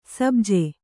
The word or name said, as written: ಸಬ್ಜೆ